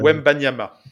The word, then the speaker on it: Wembanyama: Victor euh, Wembanyama.